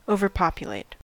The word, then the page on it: overpopulate: American English